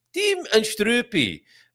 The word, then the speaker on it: Tim und Struppi: So to recap, Tintin is called Tim und Struppi in Germany and Kuifje in Amsterdam. And you do have to say it like that.